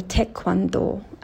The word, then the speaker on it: taekwondo: Ki-jun-i was once a taekwondo player, okay? Or taekwondo, as we pronounce it in Korean.